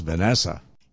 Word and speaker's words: VNSSA: This version done by Girl Math, which is an offshoot of VNSSA. Let's say that's pronounced Vanessa.